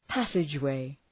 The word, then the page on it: passageway: ‘pæsıdʒ,weı